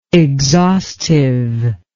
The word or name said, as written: exhaustiveness